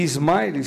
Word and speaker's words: Ismaily: E o Smiley tem uma história engraçada, porque o nome dele, Smiley, se escreve com I-S-M-A-I-L-Y. Smiley.